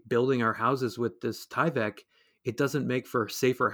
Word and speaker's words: Tyvek: And on Instagram, I've noticed too that you're producing these highly abstract pieces of embroidery out of Tyvek. Is that how it's pronounced? This industrial material, Tyvek? – Tyvek, yes.